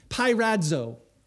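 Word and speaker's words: Pirazzo (πειράζω): Yeah, how do you pronounce that? – Pirazzo.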